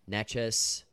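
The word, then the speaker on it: Nečas: We are taking Carolina plus 103 on the money line against the Rangers tonight, plus 103 money line, as well as Martin Neches. Is that is that how you say it? Neches? – Yeah. Neches? Yeah. Neches? Yeah. yeah. Neches. Okay, there you go.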